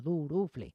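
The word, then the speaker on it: Duruflé: A ver, se escribe Durufle, así como suena, Durufle.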